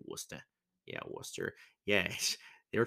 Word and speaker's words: Worcester: I said it right. It's Worcester. They were talking about that on the pesky podcast.